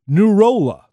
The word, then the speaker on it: Nouroulah: Nurola. Now that's spelled N-O-U-R-O-U-L-A-H.